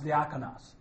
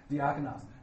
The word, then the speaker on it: diakonos: But the word then phonetically would be pronounced diakonos. All right, diakonos.